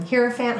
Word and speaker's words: Hierophant: Hierophant, Hierophant.